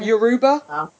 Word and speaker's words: Yoruba: It's Yoruba. No, it's Yoruba. Y O R U B A.